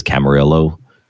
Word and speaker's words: Camarillo: I literally said Camarillo and then thought I had said it wrong because everybody says Camarillo as a joke. But anyway, it is Camarillo in Camarillo, California.